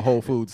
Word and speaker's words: Whole Foods: She can't even spell Whole Foods. Hey. Let alone say it. – W Whole Foods. – Oh. You don't pronounce the W in the hole.